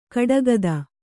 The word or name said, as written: ಕೊಡಗಿತಿ